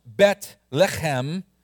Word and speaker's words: Bethlehem: Secondly, Bethlehem, if you want to be a little bit more accurate in the way it's pronounced, Bethlehem, Bethlehem. Can we say that together? Bethlehem. Thank you. I like that. I heard the throaty Bethlehem.